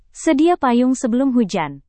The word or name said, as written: sedia payung sebelum hujan